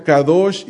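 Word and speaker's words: kadosh (קדוש): Incluso cuando uno estudia el significado de la palabra santo, Dios es una palabra en hebreo que se pronuncia kadosh y tiene que ver con cortar algo, con separarlo, con ponerlo aparte.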